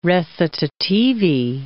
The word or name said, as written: recitativi